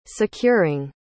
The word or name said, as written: securing